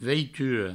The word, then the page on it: veitura: veituro